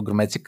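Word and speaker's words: Krmenčík: Αυτά από τον κόσμο για το Μιχαλάκη τον Κρουμέτσικ, ο οποίο, ε, παιδιά, νομίζω τον λέμε ήδη λάθο, Έχω την εντύπωση ότι κάπου διάβασα ότι τονίζεται το όνομά του στο «ρ» στην αρχή.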